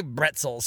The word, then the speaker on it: bretzel: Because I fucking love that pretzels is just bretzels. – Bretzels. – Yeah, it's spelled exactly it's the same way, except yeah, it's French. A B. They say bretzel in French.